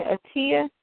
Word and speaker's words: Atia: Is soror Anita's baby name Atia? Is that how you spell her name or say her name? – Yes, it's Atia.